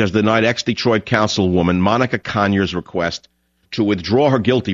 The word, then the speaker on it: Conyers: You know John Conyers, the quiet, soft-spoken John Conyers, with an emphasis on the first syllable of his last name, John Con.